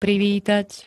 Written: privítať

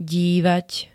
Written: dívať

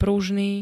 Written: pružný

